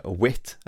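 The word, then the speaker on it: Whit: It's directed by a kind of a luminary, Wilt Stillman. – Okay. – Wit, I think you pronounce his name, sorry.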